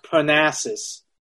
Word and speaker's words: Parnassus: I think it's pronounced Parnassus. – Parnassus? – Yeah, Parnassus. I think that's how they pronounce it.